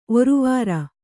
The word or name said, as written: ಒರುವಾರ